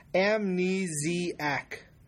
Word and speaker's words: amnesiac: Anyway, Ungaji also tweets, proper pronunciation of amnesiac is amnesiac. – That's true, because we mispronounced that last time.